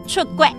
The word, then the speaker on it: 出國: 出国。